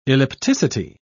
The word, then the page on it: ellipticity: British English